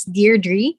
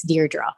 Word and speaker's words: Deirdre: My name, it's Deirdre. I follow the pronunciation of the Beach Boys, but actually in Europe, it's Deirdre.